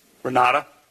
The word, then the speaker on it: Renate: Renata. – Renata. It's spelled with an E at the end, R-E-N-A-T-E.